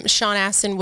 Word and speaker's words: Sean Astin: They said on an interview that Sean Astin would, is that how you say it? – Yeah.